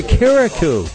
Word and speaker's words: Carriacou: We're going to Carriacou.